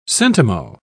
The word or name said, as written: centimo